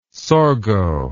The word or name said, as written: sorgo